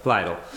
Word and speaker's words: Lidl: Lidl. That whole area, Lidl. That part of Lidl, I don't remember how to pronounce it. It's rhymes with needles.